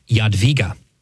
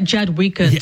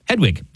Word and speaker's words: Jadwiga: This is Saint, it's pronounced Jadwiga. But it looks like Jadwiga to me. – I know, exactly. Okay. Well, you can also... – Just to hurt your wife who's listening. – Yeah, you can also pronounce her name as Hedwig. That's the German version.